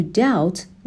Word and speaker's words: doubt: They had the confident humility to doubt their judgments. Doubt بی تلفظ نمیشه،